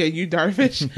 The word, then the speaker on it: Yu Darvish: Yeah, Yu Darvish.